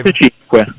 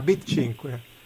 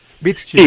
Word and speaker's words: Beats 5: Intanto come si legge? Perché che è Beats number 5? – Beats 5. – Ah, Beat 5. – Beats 5. Sì,